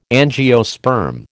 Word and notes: angiosperm: American English

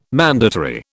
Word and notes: mandatory: British English

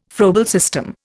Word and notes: Froebel system: Indian

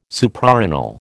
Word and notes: Suprarenal: American English

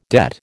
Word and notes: debt: American English

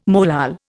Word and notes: molal: Indian

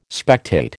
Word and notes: spectate: American English

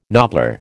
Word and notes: nobbler: American English

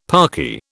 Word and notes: parky: British English

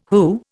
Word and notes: WHO: Indian

